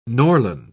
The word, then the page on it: norland: British English